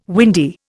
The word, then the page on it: windy: Indian